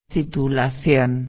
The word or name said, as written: titulacion